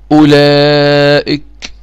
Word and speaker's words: أولئك: هذه الواو التي بعد الهمزة لا تنطق. كذلك أولي، هكذا نحن نقول إيه؟ أولى، أولي، أولئك، أولئك، أولي، لا أنطق الواو، لا أنطق الواو، هكذا.